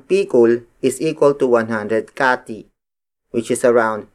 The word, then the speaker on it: kati: The next unit of weight is kati. Kati. Kati. Kati is spelled as K-A-T-I.